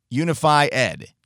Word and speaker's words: Unify Ed: It's spelled out Unified, but it's Unify Ed. Unify Ed, kind of a play on the words.